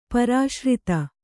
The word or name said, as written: ಪುರುಷಾರ್ಥ